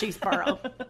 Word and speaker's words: Cheseborough: Say that again. – Cheeseborough. Spell it. C H E S C H E S E B O R O U G H.